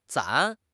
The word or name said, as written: 攒